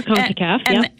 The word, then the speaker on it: CONCACAF: Am I saying that right? CONCACAF? – Yes, you are. CONCACAF.